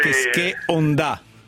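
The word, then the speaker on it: Keisuke Honda: Hai letto che il Milan oggi ha specificato come si pronuncia, sì. Kesche Onda.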